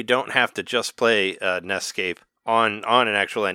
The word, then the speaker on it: NEScape: I always called it NESCAPE because NES... S starts escape, so it's kind of a wordplay. I always enjoyed that, but people started pronouncing it as an escape.